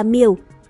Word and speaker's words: miểu: Vậy ba chữ thủy đọc là miểu, có nghĩa là lũ lụt, nghĩa là gió lớn.